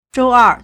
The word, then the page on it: 周二: 週二; zhōuèr